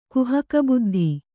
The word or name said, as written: ಕುಹಕಬುದ್ಧಿ